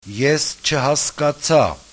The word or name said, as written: eschhaskatsa